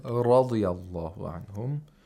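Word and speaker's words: Radiyallahu anhum (رضي الله عنهم): Radiyallah is incorrect. It's not radiy. – We need the tapping sound, as you said. – Radiyallahu anhum.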